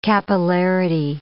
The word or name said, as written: capillarity